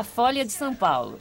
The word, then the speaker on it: Folha de São Paulo: Por favor, la pronunciación que me dijiste antes, por favor, es en portugués. – A Folha de São Paulo.